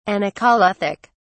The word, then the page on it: anacoluthic: British English